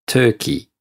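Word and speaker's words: Turkey: 英語でトルコはトゥーキーっていう発音をします。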